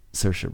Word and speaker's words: Saoirse: Is that how you say it? – Saoirse Ronan.